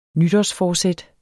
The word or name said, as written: nytårsforsæt